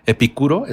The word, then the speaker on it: Epicuro: Pues bueno, hablando de, espero pronunciarlo bien, Epicuro, ¿está bien dicho? Claro.